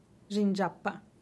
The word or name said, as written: dxindxpa